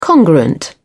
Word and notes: congruent: British English

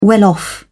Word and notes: well-off: British English

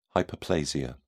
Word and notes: hyperplasia: British English

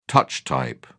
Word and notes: touch type: British English

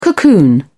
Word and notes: cocoon: British English

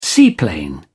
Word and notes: seaplane: British English

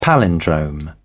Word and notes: palindrome: British English

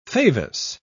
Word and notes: favus: British English

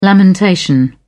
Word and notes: lamentation: British English

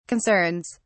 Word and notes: concerns: British English

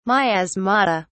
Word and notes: miasmata: British English

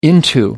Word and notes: into: American English

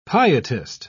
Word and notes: pietist: British English

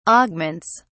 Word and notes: augments: British English

